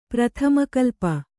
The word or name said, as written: ಪ್ರಥಮಕಲ್ಪ